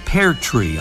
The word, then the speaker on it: pertrix: And here's a clue about the pear tree idea. An old French word for partridge was spelled P-E-R-T-R-I-X and pronounced something like, right, pear tree.